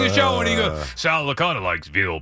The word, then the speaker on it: Licata: And then, like, the first time I worked with him, did anybody ever tell you that Sal Licata rhymes with veal piccata?